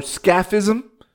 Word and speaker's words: scaphism: And it's called the tub torture or scaphism. Right? Correct me if I pronounce it wrong. S-C-A-P-H-I-S-M.